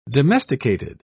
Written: domesticated

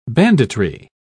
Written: banditry

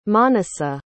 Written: Manasa

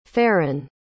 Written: farran

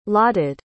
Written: lotted

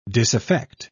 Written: disaffect

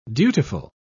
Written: dutiful

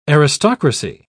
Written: aristocracy

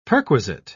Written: perquisite

